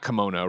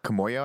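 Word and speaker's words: Kimoyo: Everett sees the, I always say it wrong, not kimono, right? – Kamoyo, I think.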